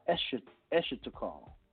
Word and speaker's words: eschatocol: See, the end of a treaty, okay, and this is, you know, I want to write this down, it's called the escheatocall, okay, or the closing protocol, and it's spelled E-S-C-H-A-T-O-C-O-L.